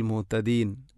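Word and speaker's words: മുഹ്തദീൻ (مهتدين): വഹുവ അഅ്ലമു ബിൽ മുഹ്തദീൻ ഓതിപ്പോകുമ്പോൾ മുഹ്തദീൻ എന്ന് പറയുമ്പോൾ ഹ ഇൻ്റെ ഉച്ചാരണം നമ്മളുടെ പാരായണത്തിൽ വരുന്നില്ല. അപ്പോൾ ബിൽ മുഹ്തദീൻ മുഹ്തദീൻ എന്ന് തന്നെ ഉച്ചരിക്കണം.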